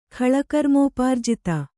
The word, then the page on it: ಖಳಕರ್ಮೋಪಾರ್ಜಿತ: kannada